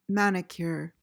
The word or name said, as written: manicure